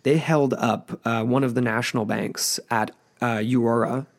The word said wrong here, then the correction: Euroa: Am I saying that right? Euroa in Victoria for around £2,000.